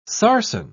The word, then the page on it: sarsen: British English